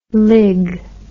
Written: lig

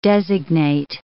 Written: designate

